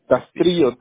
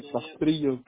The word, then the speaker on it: Castrillo: Castrillo. – ¿Castillo? Cristian. – Castrillo, TR, Castrillo.